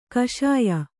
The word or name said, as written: ಕಷಾಯ